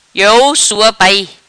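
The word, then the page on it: Iou Sua Pai: Patuá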